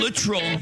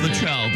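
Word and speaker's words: Littrell: The guest is Brian Luttrell from the Backstreet Boys' son. And I always thought it was Luttrell, but I'm told it's Luttrell and to never say it like that again, so I won't.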